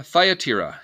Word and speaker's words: Thyatira: Thyatira? Thyatira? Thyatira is how it's pronounced.